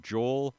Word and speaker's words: Joel: Joel rhymes with soul for a reason.